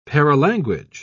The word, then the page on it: paralanguage: British English